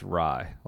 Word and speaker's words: Rai: It's not Ray, it's Rai, like rye bread.